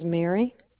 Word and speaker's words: Mary: First name is Mary, M-A-R-Y.